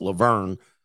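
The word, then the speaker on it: La Vergne: That's a ghetto city in Tennessee, and it's pronounced Laverne.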